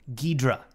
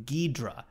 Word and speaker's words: Ghidra: Ghidra. That's okay. How you say it. Yeah. – Ghidra. – Yeah. Unless any, I had to, because I was honestly, I was going to say like Ghidra or something. Mm. Or Ghidra, that's what I was thinking of. But I had to look up videos, and they were saying Ghidra. That's how you pronounce it.